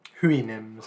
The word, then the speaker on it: Houyhnhnms: Houyhnhnms.